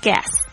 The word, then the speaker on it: guests: 那通常客人不会有一个，所以会变成 guests。大家特别注意一下那个 T-S 的发音是，这个声音是牙齿这个齿缝发出来的这个声音。